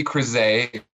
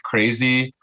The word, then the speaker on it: Creasy: Riley Creasy is how you say it. Kind of a hard last name to pronounce. So most people just call me like Creasy. – Perfect. – Or Creasy. I really respond to everything at this point.